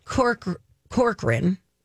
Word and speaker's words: Corcoran: And Barbara Corcoran, right? Am I saying that right? – Mm-hmm. – Corcoran. – Yeah.